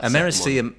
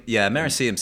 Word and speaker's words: Americium: Krypton, America. – I've probably butchered that. – Americium, Americium sounds good.